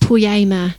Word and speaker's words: poiema: And the word for workmanship in here is a Greek word, and it's pronounced poiema.